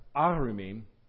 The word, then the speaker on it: arumim: Where it says they knew that they were naked, it's almost exactly the same word in 2:25, the one that's pronounced arumim. Here's where it gets nerdy. But it's pronounced in this place erumim. Just a slight little difference in how it's vocalized.